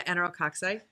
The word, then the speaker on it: enterococci: Industrial dog food is a vehicle of multi-drug-resistant enterococci. Am I saying that right? – Yeah, enterococci.